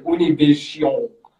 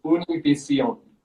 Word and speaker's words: Univision: And I said, "Univision." I said a French pronounce, like I pronounced it like very French-like, but it is Univision.